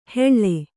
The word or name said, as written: ಹೆಳ್ಳೆ